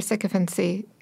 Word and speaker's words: sycophancy: He has set new standards for sycophancy, a word that I don't know how to pronounce. – Sounds right. – You nailed it.